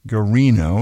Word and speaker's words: Guarino: It's Elizabeth Hamilton Garino, and her last name is spelled G-U-A-R-I-N-O.